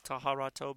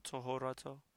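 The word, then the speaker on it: טהרתו: It looks like taharato, but it is pronounced tohorato.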